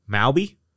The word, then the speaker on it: Mawby: Mobby or Moby? – M-A-W-B-Y. Mowby? – Spell it one more time for me. – M-A-W-B-Y.